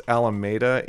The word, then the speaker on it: Alameda: So I think that's actually pronounced Alameda in Los Angeles, where he's from. Or where he was when he recorded it. But he's actually from Portland. But in Baltimore, there's a road called the Alameda, which is spelled the same way.